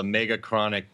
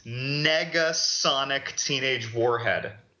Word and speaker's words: Negasonic Teenage Warhead: He said Megachronic. Instead of Mega, throwing an N, and it's Negasonic Teenage Warhead.